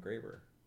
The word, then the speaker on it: Graber: Adam Graber.